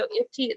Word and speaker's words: IFTTT: So IFTY, it's spelled IFTTT.